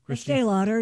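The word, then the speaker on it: Estée Lauder: Estee Lauder, yes.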